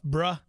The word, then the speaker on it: bruh: Take that off, bruh." Is that how you pronounce that? B-R-U-H? – Yeah, yeah, yeah. – Is it bruh? – Bruh. – Bruh. Take that off, bruh.